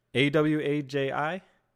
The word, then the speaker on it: Awaji: Awaji Island, Awaji, Awaji. – Spell it. – A W A J I.